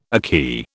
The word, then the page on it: akee: British English